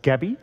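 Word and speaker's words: Gaby: But tell us, the first character in Debussy's story is a woman by the name of Gabby. Am I saying that right? – Yep.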